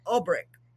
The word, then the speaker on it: Ulbrich: This is It Hot Enough For You by Carla Ulbrich.